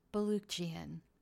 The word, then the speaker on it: Balukjian: I also have an interview coming up with best selling, I like saying that, Brad, best selling baseball book author uh, Brad Balukjian. I know I said that right because I wrote down the pronunciation that he gave me.